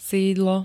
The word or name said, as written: sídlo